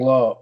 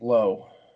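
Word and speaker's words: Lowe: So please, I would like to think I did a little piece on Fantasy Alarm about is it Lau or Low? I want to say it right. – Brandon Low, it's Nate Low.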